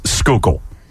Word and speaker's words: Schuylkill: As in Schuylkill Expressway, you just heard it there. – S-C-H-U-Y-L-K-I-L-L, Schuylkill. – Nailed it, Bob Cooney. – Yeah, here is Boban Marjanovic and Tobias Harris attempting to pronounce Schuylkill.